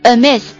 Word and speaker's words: amiss: 那我们在这个单词前面加上一个字母 A， a miss，注意一下发音 a schwa sound， a miss 就变成形容词，表示不对、不正常、有缺陷、出差错了、出差错了。